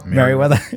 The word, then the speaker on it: Merewether: But when I think about Sally and I think about Meriwether with the upcoming event, am I saying that right? Meriwether, or is that Meriwether? – Meriwether.